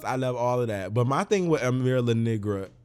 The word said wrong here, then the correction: Amara La Negra: or how you say it? – Amara La Negra.